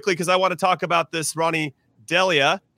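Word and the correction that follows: Deila: Did I say it right? Delia. Ronnie Dyla. Thank you for that, Charlie.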